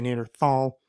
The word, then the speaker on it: Neanderthal: so that becomes tal, so Neanderthal, and when it goes through English, because there is, it's spelled Neander, T-H-A-L, because it's spelled that way, when it came through English, it became a th sound, so the English say Neanderthal, the correct, quote-unquote correct, would be Neanderthal, because that's where the original valley came from.